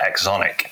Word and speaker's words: AxonIQ: I am the lead developer on the Axon framework over at Axonic. Indeed, it's Axon IQ spelled together, but we decided to pronounce it as Axonic.